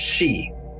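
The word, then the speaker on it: sidhe: or Sids, often pronounced She.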